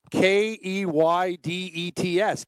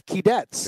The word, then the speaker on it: Keydets: Yeah, the Keydets. K e y d e t s. Keydets. – It is. Eh? Keydets.